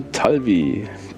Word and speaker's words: Talvi: I am Talvi. T-A-L-V-I.